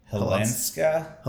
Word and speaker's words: Hilanska: I think you got it right. Hilanska? – Hilanska. Yeah, that sounds right.